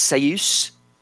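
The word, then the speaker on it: Ceus: We then had the announcement of Steward, and I want to say this properly, I believe it's Seyus, who is a Haitian national team goalkeeper.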